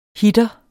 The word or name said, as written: hitter